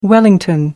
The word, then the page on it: wellington: American English